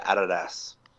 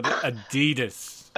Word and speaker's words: Adidas: So, for those that don't live in Queensland, we also pronounce it Adidas. – Oh, well, I call it Adidas.